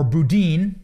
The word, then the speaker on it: Boudin: Bowden's loss, or Boudin, I've been saying it wrong.